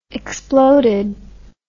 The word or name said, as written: exploded